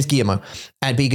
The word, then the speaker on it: Guillermo: Guillermo, even though it's got L's in it.